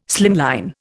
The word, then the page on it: slimline: Indian